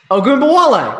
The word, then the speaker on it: Ogunbowale: Ogunbowale, right? – Ogunbowale. – Right. All right, I'm losing a syllable in there.